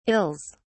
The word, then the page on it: ills: British English